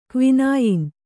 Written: ಕ್ವಿನಾಯಿನ್

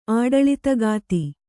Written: ಆಡಳಿತಗಾತಿ

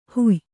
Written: ಹೊಯ್

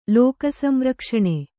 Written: ಲೋಕಸಂರಕ್ಷಣೆ

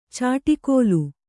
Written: ಚಾಟಿಗೋಲು

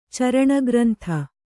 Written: ಚರಣಗ್ರಂಥ

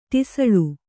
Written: ಟಿಸಳು

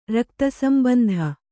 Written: ರಕ್ತಸಂಬಂಧ

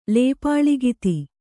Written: ಲೇಪಾಳಿಗಿತಿ

